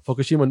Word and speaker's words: Fukushima: Japan's government confirms it will release over 1 million tons of contaminated water from the Fukushima. Did I say it right? – Fukushima nuclear plant into the sea after the water is treated extensively for a number of years.